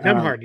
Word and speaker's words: Nembhard: And then, obviously, we had that big breakout from second-round draft pick Andrew, and I always butcher his name, Nembhard. – Yep, Nembhard.